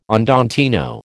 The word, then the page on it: andantino: American English